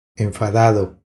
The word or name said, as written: Enfadado